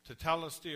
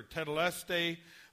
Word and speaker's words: tetelestai: And the Greek word used for "it is finished" is, depending how you pronounce it, tetelestai or tetelestai, but it means paid in full.